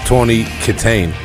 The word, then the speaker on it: Tawny Kitaen: Uh, I believe her name is pronounced Tawny Kitaen.